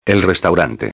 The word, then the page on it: el restaurante: espanhol